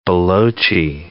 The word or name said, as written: Balochi